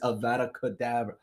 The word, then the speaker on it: Avada Kedavra: I said, it's Avada Kedavra.